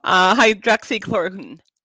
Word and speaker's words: hydroxychloroquine: Hydroxychloroquine.